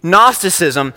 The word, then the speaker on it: Gnosticism: And there was this line of thought called Gnosticism, silent G, Gnosticism.